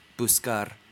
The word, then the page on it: fetch: /fɛtʃ/